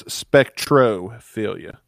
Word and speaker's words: spectrophilia: I've been saying it wrong. It's spectrophilia.